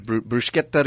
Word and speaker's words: Bruschetta: Oh, they have a great food truck, the Bruschetta food truck. Did I say that right? – Yeah, that sounds good.